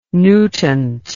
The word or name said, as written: nutant